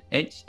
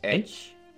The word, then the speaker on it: Edge: ¿Cómo se pronuncia? Edge. Edge. Edge.